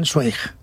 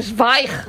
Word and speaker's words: Zweig: Tú sabes que yo toda mi vida he estado diciendo que yo leía Stephen Zweig. – Ah, claro. Es no. Zweig.